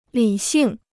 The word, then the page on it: 理性: lǐ xìng